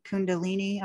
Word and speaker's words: Kundalini: I want to ask you, I was fascinated when you mentioned the Kundalini, I believe. Did I say that right? – Mm-hmm. You can say it.